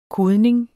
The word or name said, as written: kodning